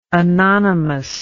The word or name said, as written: anonymously